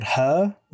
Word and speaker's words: Hur: anyway, this character, Old Mother, and another cave woman who's called Her, which is spelled H-U-R, but is just Her.